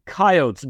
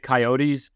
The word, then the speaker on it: Coyotes: Before I lived in South Dakota for 4.5 years, I pronounced it Coyotes. I was certainly ridiculed for that by my wife and other natives of the fine state of South Dakota.